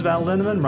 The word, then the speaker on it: Linneman: Jody Friesner, Ruth Ann Funderburk, Misha Rosen, Michael Warbeck, the equally mysterious Tracy Z, Aviva Lael, Alicia Lannis, Rebecca Hughes, Val Linneman, Rhymes with Cinnamon,